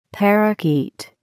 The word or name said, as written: parakeet